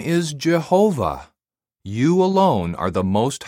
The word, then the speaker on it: Jehovah: In each language, it is pronounced differently. In English, it is usually pronounced Jehovah, but some people pronounce it Yahweh.